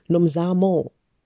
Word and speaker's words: Nomzamo: I don't even know if I if I pronounce that correctly. Nomzano Nomzamo say that right, Mbatha.